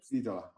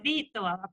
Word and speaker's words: Vitola: I pronounce it correctly, Vitola, Vitola. – No, very good, very good. – Vitola, good. – It's actually in Latin we say Vitola, but Vitola. – International Vito is okay.